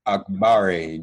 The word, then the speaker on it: Akbari: Senator Ramesh Akbari joins us now. First of all, Senator, am I pronouncing your name correctly? – It's actually Akbari, like Blackberry, but you killed the first name.